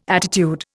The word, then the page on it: attitude: Indian